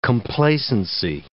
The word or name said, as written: complacency